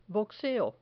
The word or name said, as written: boxeo